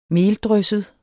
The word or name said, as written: meldrysset